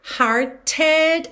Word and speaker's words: hearted: De novo aqui, hearted, esse E final é pronunciado. E o T foi modificado para rarara, por isso está pintadinho de azul claro.